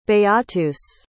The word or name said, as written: beatus